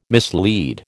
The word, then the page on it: mislead: American English